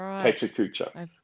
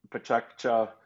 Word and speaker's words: PechaKucha: So we say pachakcha, that's the shortened Japanese format of pechakucha.